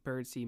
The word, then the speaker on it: Birdseye: I have seen some hubbub about the name pronunciation and how it is actually McPherson, and his middle name is actually pronounced Birdsey, where it's spelled Birdseye.